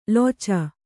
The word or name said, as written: ಲೊಚ